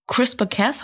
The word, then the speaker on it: CRISPR-Cas: Stichwort, was ich sehr faszinierend finde, worüber viele reden, in der Genetik des CRISPR-Cas, habe ich es richtig ausgesprochen? Wohl ein ganz einfaches Verfahren, was sehr, sehr effektiv ist, eine Genschere, mit der ich wirklich Krankheiten aus meiner DNA eliminieren kann. – Ja, ist richtig.